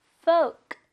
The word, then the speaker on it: folk: 네, 포크가 아니고요. folk, folk tale.